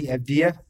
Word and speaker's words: Avdija: Denny Avdija.